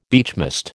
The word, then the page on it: beechmast: American English